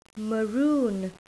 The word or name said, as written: maroon